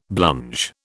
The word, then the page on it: blunge: British English